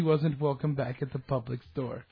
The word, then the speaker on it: Publix: Yeah, it's spelled P-U-B-L-I-X, public.